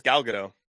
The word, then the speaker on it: Gal Gadot: I think it's Gal Gadot. – Gal Gadot. Yeah, it's Gadot. It's kind of to what we would pronounce it. I researched it.